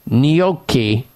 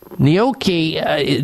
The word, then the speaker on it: gnocchi: Feed your family of four gnocchi.